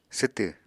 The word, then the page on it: swtw: apatani